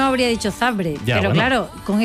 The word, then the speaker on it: Zabrze: Zabrze.